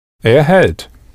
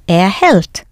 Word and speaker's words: hält: Er hält. – Er hält.